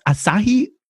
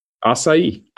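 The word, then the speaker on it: açaí: And how is it pronounced? Asahi? – Acai.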